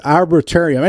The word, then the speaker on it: arboretum: And I also got a chance to visit their I don't know how to say it, but the arboretarium. arbor, arbor, arboretum.